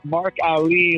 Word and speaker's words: Mark Allee: So I finally got Mark Ali. Is that how you pronounce it? – An easy way to remember is Mark Ali, like Muhammad Ali, spelled differently.